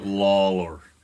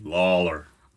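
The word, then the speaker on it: Lollar: Ryan said you got to say it like you're... – like a cowboy. – Like a cowboy, like Lawler. What's his name? The John Wayne. You got to say it like John Wayne.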